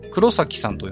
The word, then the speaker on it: 黒咲: 黒崎さんと読みするんでしょうか、これは。あ、そうです。